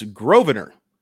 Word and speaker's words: Grosvenor: It's Grosvenor. Lucan Grosvenor, right? Baker, and that's spelled Grosvenor. The S is silent.